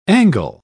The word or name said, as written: angle